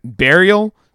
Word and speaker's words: Burial: Burial. – Like a bear, like an animal? That's how you say it? – Yes. That's how you say it. – Okay. Burial Beer Company.